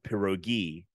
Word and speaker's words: pierogi: The Russian plural pierogi, pierogi, with the stress on the last syllable, should not be confused with pierogi in Polish cuisine, which are dumplings similar to Russian pelmeni or Ukrainian varenki.